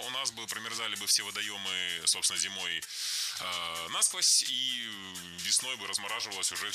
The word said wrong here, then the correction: насквозь: Насквозь неверно. Ударение в этом наречии должно падать на второй слог. Насквозь. Так зафиксировано в словарях. Только насквозь.